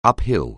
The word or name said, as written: uphill